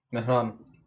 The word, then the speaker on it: Miran: How about, like, in your accent? Like, how would you say it? – Miran.